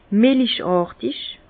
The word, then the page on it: melichàrtig: Bas-Rhin; Herrlisheim